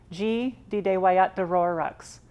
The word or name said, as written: Tsi Titewaya’taró:roks